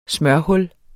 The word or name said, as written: smørhul